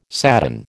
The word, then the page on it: sadden: American English